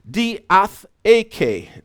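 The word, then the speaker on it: diatheke (διαθήκη): Is a word pronounced diatheke. Diatheke. All right.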